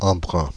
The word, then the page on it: embrun: France (Île-de-France); /ɑ̃.bʁœ̃/; Paris; [ɑ̃.bʁœ̃]